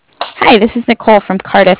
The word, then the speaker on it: Cardiff: From Cardiff. Did I say that right? – Cardiff, like car and different.